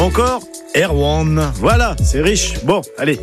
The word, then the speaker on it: Erwan: Mais la seule chose est sûre, Santerwan se prononce comme ça quand on parle de lui. Car il était originaire du Trégor, et là-bas on disait Erwan.